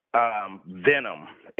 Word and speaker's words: Venomm: Yeah, they can find me under Joski Storyteller or Venom, and it's spelled V-E-N-O-M-M.